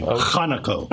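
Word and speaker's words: Conaco: Is it Conoco or Conoco? – I like to give it a Hebrew pronunciation. Conoco. – What actually is it? – It's Conoco.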